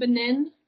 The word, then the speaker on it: Benin: So surrounded by Ghana, and then am I pronouncing Benin right? – Correct.